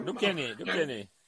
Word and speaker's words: Duquesnay: Duquesnay.